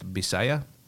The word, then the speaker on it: Bisaya: In the Philippines, they oftentimes pronounce a V as in Victor as a B, as in boy. And so even though it's spelled with a V, it's pronounced Bisaya.